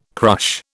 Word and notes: crush: British English

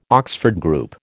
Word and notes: Oxford Group: American English

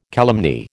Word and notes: calumny: American English

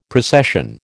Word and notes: precession: American English

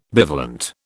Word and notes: bivalent: British English

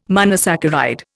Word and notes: monosaccharide: Indian

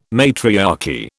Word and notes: matriarchy: British English

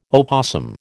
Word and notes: opossum: American English

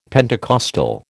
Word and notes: Pentecostal: American English

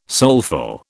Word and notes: soulful: British English

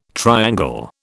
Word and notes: triangle: British English

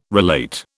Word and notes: relate: British English

